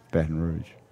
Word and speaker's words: Baton Rouge: How do you pronounce Baton Rouge? – Baton Rouge. – Not Rouge.